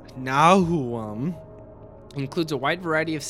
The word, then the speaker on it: NWOAHM: The new wave of American heavy metal scene, commonly abbreviated as NWOAHM, so Nwahom. – Wait, spell it again? – NWOAHM. – What? – Nwahom.